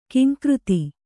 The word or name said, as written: ಘೀಂಕೃತಿ